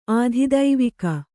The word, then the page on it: ಆಧಿದೈವಿಕ: ādhidaivika